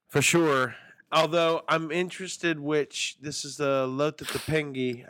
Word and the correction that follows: Lopetegui: I can't say his name. Maybe we should have a how to say his name. Lopetegui. – Lopetegui. – That sounds right. – Lopetegui.